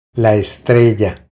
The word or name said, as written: La estrella